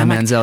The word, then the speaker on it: Manziel: Like those people can have like a 5-hour conversation about Johnny Manziel or however you say his name. – Yeah, Manziel.